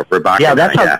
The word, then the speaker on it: Rybakina: Yeah, I want to get the accent on the right syllable there. Rybakina.